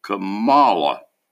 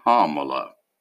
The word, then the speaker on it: Kamala: I thought the accent was on the second syllable, Kamala. It's not. It's Kamala.